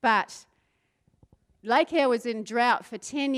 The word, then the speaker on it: Eyre: I wanted to show you quickly Lake Eyre. I said that right.